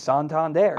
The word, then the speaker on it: Santander: He asked the guy, and he was like, it's Santander.